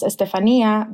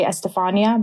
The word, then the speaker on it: Estefanía: My name is pronounced Estefanía, but in America, people usually say Estefania because it's spelled with an F.